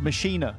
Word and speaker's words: Maschine: But it's German, so it's pronounced Maschine.